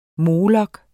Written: molok

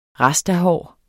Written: rastahår

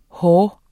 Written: hår

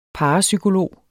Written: parapsykolog

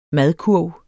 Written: madkurv

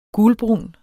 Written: gulbrun

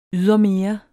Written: ydermere

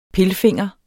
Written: pilfinger

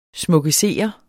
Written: SMUKKESERER

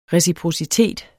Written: reciprocitet

